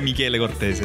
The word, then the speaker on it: Michele Cortese: Estoy preparado a decirlo como se pronuncia Michele Cortese, difícil acá.